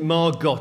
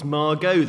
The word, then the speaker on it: Margot: And Margot Asquith says to her, look, you know, my name isn't Margot, it's Margot, the T is silent.